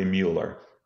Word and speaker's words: Mueller: So are you a Miller or a Mueller? Those German diphthongs always get me. – Those of us in the West are typically Mueller.